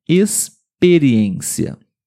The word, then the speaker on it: experiência: As regras para esse som é quando a letra X ela vem antes das letras P e T. P de papai e T de tatu. Por exemplo, experiência. Sim. Experiência. Também se escreve com a mesma letra X, mas aqui o som é S.